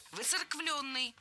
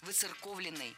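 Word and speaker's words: воцерковлённый: Выцерквленный. Здесь сразу две ошибки в одном слове. Во-первых, пропущено «о». Во-вторых, неправильное ударение. Слово должно звучать так. Выцерковленный. В качестве шпаргалки предлагаю однокоренное прилагательное. Церковный, выцерковленный. Далее у нас на очереди краткая форма того же слова.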